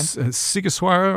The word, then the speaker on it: Sighișoara: Sighișoara or Sighișoara is the second.